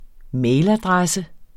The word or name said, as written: mailadresse